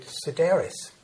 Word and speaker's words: Sedaris: My guest is David Sedaris.